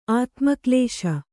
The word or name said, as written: ಆತ್ಮಕ್ಲೇಶ